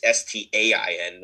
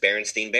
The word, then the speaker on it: Berenstain: I mean, it's Stain, not Stein. – Yeah. – But nobody ever pronounced Berenstain. You know, it sounds silly to say it that way. It's just Berenstain Bears.